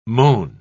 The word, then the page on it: mown: British English